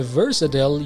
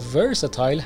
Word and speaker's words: versatile: کلمه versatile یا versatile هر دو تلفظ درست هستن